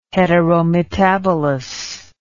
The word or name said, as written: heterometabolous